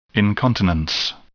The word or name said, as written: incontinence